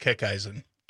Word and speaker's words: Kickeisen: It's pronounced Kickeisen. – Kickeisen. – Yep. – Kickeisen. Yeah. – Yeah. Slightly German.